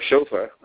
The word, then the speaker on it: chauffeur: I think being a chauffeur is that, or chauffeur, I got to keep remember the accent is on the first syllable, to keep himself moving, to keep himself alive.